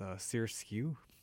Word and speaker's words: SRSQ: And to start off the program tonight, you're going to hear an artist that I checked out last night, and that's Sirsku, and that's spelled S-R-S-Q, and it's an artist out on the Dace Records label.